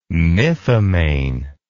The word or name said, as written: mythomane